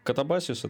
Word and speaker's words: Катабасис: Катабазии, как это правильно произносится? Расскажи, пожалуйста. – Катабазия? Ну, Катабасис — это как Анабасис, только наоборот.